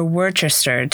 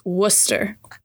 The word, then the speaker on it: Worcester: Is it Worcester, sir? – It's Worcester.